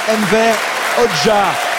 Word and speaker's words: Enver Hoxha: Avec la prononciation en plus, Enver Hoxha, le nom de cette personnalité politique, ce dictateur, puisque il était à la tête d'un régime très répressif en Albanie.